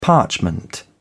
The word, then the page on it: parchment: British English